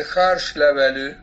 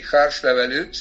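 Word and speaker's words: Hárslevelű: La segunda cepa en importancia, que tiene cerca del 25% de los viñedos, quizás un poco más, es el Harschlevelű, así se pronuncia en, ajá, húngaro, significa hoja de tilo en realidad, porque la hoja de la vid se asemeja un poco a la hoja del tilo.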